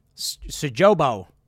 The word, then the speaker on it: Sjöbo: Crime unites voters in Swedish far-right stronghold of, how do you pronounce this, Sjöbo? It's an O with the umlaut or whatever, but I don't know how to pronounce that. Sjöbo. There you go.